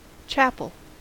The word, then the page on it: chapel: American English